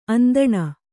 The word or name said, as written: ಅಂದಣ